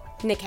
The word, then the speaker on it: Nike: It's spelled just like Nike. That's what's confusing. – Mm. Yeah. – Yeah, but it's Nike.